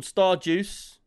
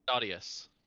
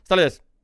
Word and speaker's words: Stardeus: I thought it was called Stardeuce. Gray come along and he told me that it's not, and I've got my whole thing wrong.